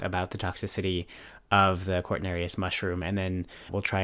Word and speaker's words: Cortinarius: Cortinarius, the mushroom, has the toxin arelanine that causes renal toxicity, because Cortinarius rhymes with Sagittarius,